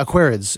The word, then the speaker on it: Aquarids: Yeah, Aquarids is usually how it's pronounced.